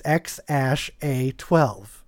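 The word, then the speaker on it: X Æ A-12: And AE is the symbol is ash. So it's literally said like it's spaced. So it's X ash A 12 is how you pronounce this name.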